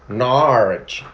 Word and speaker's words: Norwich: But they would say Norwich. The locals. – But, yeah. They say what? – Norwich. – Oh, okay. They have a bit of a drawl.